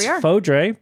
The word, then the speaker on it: Fodre: Well, it's Fodre because it rhymes with Fode, number 40.